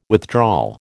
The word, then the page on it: withdrawal: American English